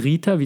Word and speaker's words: ṛta: Also das ist, das schreibt sich RTA und das R hat unten so einen Punkt. Sieht ganz komisch aus, ist eine Sanskrit-Schreibweise und äh, wird halt Rita, wie der Name Rita ausgesprochen.